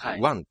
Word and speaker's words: 完: 終わるっていうのは中国語で完了の完と書いて、ワンって言うんですよね。